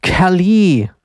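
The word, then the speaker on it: Kaliii: Das muss man so aussprechen. Da sind nämlich drei I hinten und sie sagt selber Kali.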